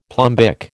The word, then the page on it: plumbic: American English